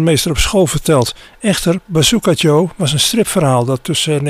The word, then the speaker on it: Bazooka Joe: Maar in het Engels spreek je dat dus precies tegenovergesteld uit. Niet Bazooka U, maar Bazooka Joe.